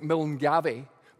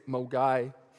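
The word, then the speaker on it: Milngavie: It's kind of, if you to read it kind of phonetically, it's like Milngavy, but we say Milngavy.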